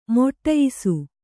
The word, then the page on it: ಮಡಿಯಿಸು: maḍiyisu